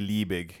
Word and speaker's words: Liebig: How would you pronounce that in German? – If it was German, it would be Liebig. – Yeah, that's what I thought. Liebig.